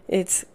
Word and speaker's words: Ai: It's spelled A-I. I think it's pronounced I.